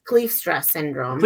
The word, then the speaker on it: Kleefstra syndrome: Yeah, it's Kleefstra syndrome.